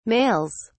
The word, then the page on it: males: British English